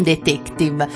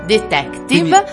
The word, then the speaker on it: detective: Quindi il suono E va pronunciato sempre aperto quando, innanzitutto, nelle parole di origine straniera che pronunciate terminano in consonante. Pensiamo a detective. Non detective, detective.